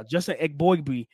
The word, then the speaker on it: Eboigbe: That's what Eboigbe, I didn't say it right. The previous pod that we talked about him, but I got it just now. Justin Eboigbe, man, like, yes, Blake Corum had a good game.